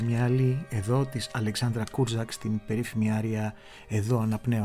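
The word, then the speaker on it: Kurzak: εδώ με την Αλεξάνδρα Κούρζακ, την πολύ σημαντική πλέον, δεν είναι το ρο δεν προφέρεται, Κούρζακ είναι κανονικά,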